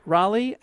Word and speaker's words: Raleigh: Possibly R-A-H-I-L-L-Y. – No, it's R-A-L-E-I-G-H. And I've heard it pronounced Raleigh and Raleigh.